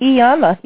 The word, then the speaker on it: Iyanla: Iyanla.